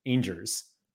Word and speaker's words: Angers: My favorite is that Mike Basson, isn't it Rangers without the R? Angers. No. Silent G too. Well, it's not a hard G.